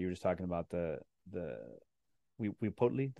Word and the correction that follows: Huitzilopochtli: did I say that correctly? – We'd see Lopochli. – Huitzilopochtli, Huitzilopochtli.